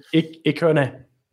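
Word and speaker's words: Ikoné: My only issue with Lille, really quickly, is that with no Iconi, is that how you say it? Iconi? – Iconi.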